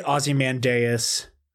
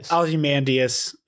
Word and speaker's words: Ozymandias: I say Ozymandias. – Ozymandias. – I've said it wrong my whole life.